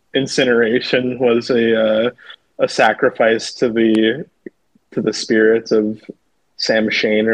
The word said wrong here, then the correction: Samhain: Sam Hain, or otherwise pronounced as Samhain.